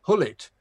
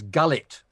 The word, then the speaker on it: Gullit: So Hullet was Gullet for a long period because they hadn't worked out that in actual fact it wasn't pronounced that way.